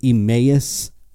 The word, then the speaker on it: Emmaus: at the, someone corrected me on how to say this, and I'm still going to screw it up. The Emmaus Theater in Allentown, Pennsylvania, and Tim Butterly is coming with me to that, so that's going to be electric.